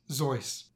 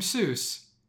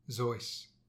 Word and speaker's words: Seuss: It's Zoyce. Teddy's friend Alexander Liang even made a rhyme to teach everyone the right way to pronounce Teddy's name. Quote, "You're wrong as the deuce, and you shouldn't rejoice if you're calling him Seuss. He pronounces it Zoyce."